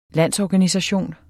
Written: landsorganisation

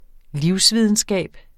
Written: livsvidenskab

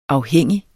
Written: afhængig

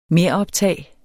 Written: meroptag

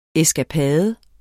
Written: ESKAPADERNES

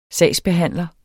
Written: sagsbehandler

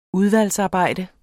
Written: udvalgsarbejde

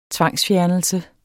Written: tvangsfjernelse

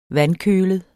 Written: vandkølet

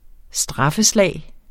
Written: straffeslag

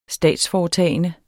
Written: statsforetagende

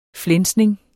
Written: flænsning

